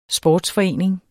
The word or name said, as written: sportsforening